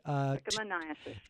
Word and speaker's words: Trichomoniasis: Trichomoniasis, is that how you say it? – Trichomoniasis.